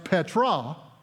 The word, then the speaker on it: Petra: The word rock is the translation of a Greek word that is pronounced Petra. Petros, Petra.